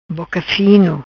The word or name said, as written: Boca Fino